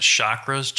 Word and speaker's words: chakras: You should pronounce it chakras, chakras. – Either way, I say chakras.